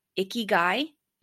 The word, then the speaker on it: ikigai: All right, ikigai. Am I saying that right? – Yeah.